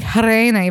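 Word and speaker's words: Gereana: Victor Jarena, and I'm saying Jarena, even though it's spelled with a G-E-R-E-A-N-A, and that's why I couldn't find it on the wiki, because Robert Stack is like over Hispanicizing Jarena, but it's a G, not an H or a J.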